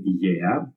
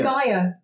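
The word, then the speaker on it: Igea (Γαία): Oh my God, I love how you pronounce this. I always said Gaia. – Yeah. It's, of course, in Latin characters, it's Gaia, but for me, this is why it's amazing to have you here. Can you please pronounce it one more time? – So it's Gaia, and Gaia is the ancient name for planet Earth.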